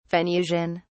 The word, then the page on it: venusian: British English